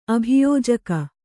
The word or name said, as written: ಅಭಿಯೋಜಕ